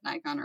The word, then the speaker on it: Nikon: How do you say Nikon? – Very German, actually. Yeah. Nikon.